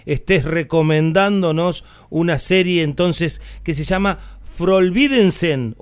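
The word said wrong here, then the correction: Forbrydelsen: o algo así. – Forbridelsen. – Ah, Forbridelsen, sí, muy bien.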